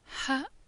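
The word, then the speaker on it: Ha (حاء): Ha is pronounced as H, but a bit more heavier than we're used to pronounce it, further down from the lungs.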